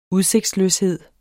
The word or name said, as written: udsigtsløshed